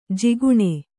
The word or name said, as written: ಜಕಣಿ